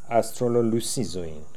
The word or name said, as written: Astrololusizoin